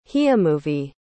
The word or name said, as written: hiamovi